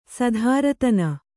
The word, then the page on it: ಸ್ತ್ರೀದಾನ: strī dāna